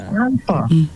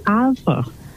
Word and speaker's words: Alpha: Alfia. – It's Alpha. – What's it? – The name is Alpha. – I'm not hearing you clearly. – Okay, Alpha. Yes. Okay, I thought it was Althea. So Alpha, spell it again. A L P H A.